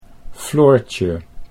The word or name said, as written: Floortje